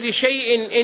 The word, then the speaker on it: لشيء: لشيء كتبت بالألف بعد الشين، فإذا قرأتها كما رسمت ستقول ولا تقولن لشيء إني فاعل ذلك غدا، وهذا خطأ. ولذلك وضعوا عليها دائرة صغيرة دلالة على أن هذه الألف لا تلفظ.